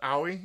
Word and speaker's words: Aoi: I think it's pronounced Aoi. It's spelled A-O-I.